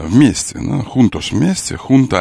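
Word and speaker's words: junta: Испанская джунта, собрание. Джунта, юнта. Как там? – Собрание. Хунта. – Там нет, так читается. – Да, так читается хунта. Это хота, звук. Хота. Это наследие арабских времен.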